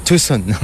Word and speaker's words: Tucson: La bonne prononciation, puisque vous dites Tucson, ça peut aller de Tucson à Tucson. Il me semblait qu'il fallait dire plutôt ici Tucson. – Oui, oui, oui.